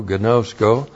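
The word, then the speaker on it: gnosko (γινώσκω): G-I-N long O-S-K long O, gnosko.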